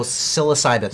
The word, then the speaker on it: psilocybin: I can never pronounce it right, but I think it's something like along the lines of psilocybin or whatever. – Oh, psilocybin. – Psilocybin, right? Yeah. There's a P at the beginning. Silent. – Silent P.